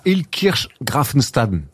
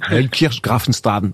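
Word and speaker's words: Illkirch-Graffenstaden: Ouais, À Ilkirch Grafenstaden.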